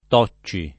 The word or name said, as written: Tocci